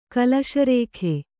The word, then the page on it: ಕುಲಸಾರಿಕೆ: kulasārike